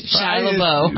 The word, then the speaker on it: Shia LaBeouf: Shia LaBeouf. – Shia LaBeouf. – Shia LaBeouf. Even though it's spelled LaBeouf.